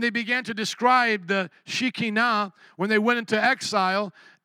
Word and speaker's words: Shekinah: The rabbi spoke so much about the Shekinah, how would you say Shekinah? Shekinah, but the Hebrew pronunciation. Shekinah. I think that's how you pronounce it with the Hebrew, the Shekinah. But thank you for the English version, the Shekinah.